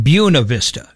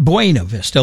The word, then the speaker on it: Buena Vista: In Colorado, they say it Buena Vista. It's not Buena Vista like everywhere else in the world. In Colorado, it's Buena Vista.